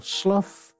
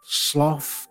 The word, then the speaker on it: Slough: It was Subtle, S-L-O-U-G-H. Subtle Slough, Subtle Slough. Various radio announcers found it an occupational hazard. Some pronounced it Slough, to rhyme with stuff, and some sloth to rhyme with cough.